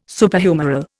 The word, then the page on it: Superhumeral: Indian